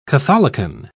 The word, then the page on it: catholicon: British English